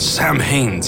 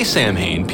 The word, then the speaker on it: Samhain: I'm glad nobody said anything about it, but I mispronounced it. You can say Sam Haines. People say it, but there's a more... There's a different way of saying Samhain. I can't say what? – It. – Samhain.